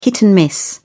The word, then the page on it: hit and miss: British English